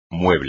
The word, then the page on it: mueble: espagnol